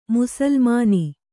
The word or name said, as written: ಮುಸುಲುಮಾನ